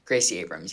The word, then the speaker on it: Gracie Abrams: Gracie Abrams, yeah.